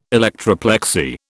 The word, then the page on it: electroplexy: British English